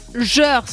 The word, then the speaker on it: Jeur: Oui, cette commune s'appelle Jeur. Ça s'écrit comme beurre, mais avec un J au début.